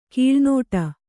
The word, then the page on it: ಕೀಳುನುಡಿ: Kannada